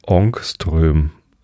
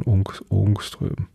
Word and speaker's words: Ångström: Ich glaube, das A mit dem Kringel oben drauf wird O ausgesprochen. Ångström.